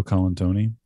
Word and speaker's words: Colantoni: Enrico Colantoni.